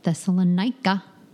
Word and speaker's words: Thessalonica: Thessalonica. Did I say that right? – Thessalonica? – Thessalonica. There you go.